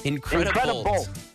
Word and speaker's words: Incredibolt: Now you're saying Increda, like the word incredible, but the last syllable is bolt, like a lightning bolt? – Yeah. – Incredibolt.